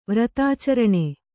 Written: ವ್ರತಾಚರಣ

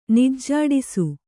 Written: ನಿಜ್ಜಾಡಿಸು

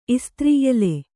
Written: ಎಲೇ

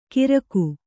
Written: ಗಿರಕು

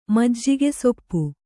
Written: ಮಜ್ಜಿಗೆಸೊಪ್ಪು

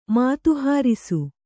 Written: ಮಾತುಹಾರಿಸು

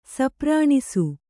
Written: ಸಪ್ರಾಣಿಸು

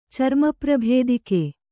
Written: ಚರ್ಮಪ್ರಭೇದಿಕೆ

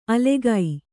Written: ಅಲೆಗೈ

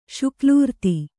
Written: ಶುಕಲೂರ್ತಿ

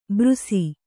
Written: ಬ್ರುಸಿ

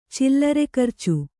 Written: ಚಿಲ್ಲರೆಖರ್ಚು

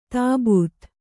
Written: ತಬ್ಬೇತ

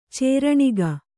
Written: ಚೂರ್ಣಿಕೆ

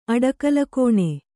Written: ಅಡಕಲುಕೋಣೆ

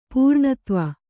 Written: ಪೂರ್ಣತ್ವ

